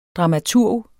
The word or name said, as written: dramaturg